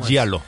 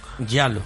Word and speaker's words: Giallo: Yalo. – Yalo. Que eso es muy típico también del Giallo bueno, del, del terror italiano, del terror italiano, este es muy típico eso de...